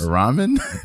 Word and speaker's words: ramen: See, my son likes Roman noodles. – Ramen? – Noodles. I don't know how to pronounce it. – And Roman is R-O-M-A-N. – No, it's R-A-M-E-N, fool.